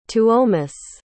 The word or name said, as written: tuomas